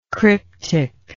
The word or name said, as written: cryptic